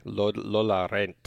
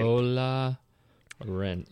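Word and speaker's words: Lola rennt: Did you look up in your little fancy schmancy translator device how to say the title properly? Lola Rent. – Lola Rent. – Lola Rent. – Lola Rent. R-E-N-N-T. Lola Rent.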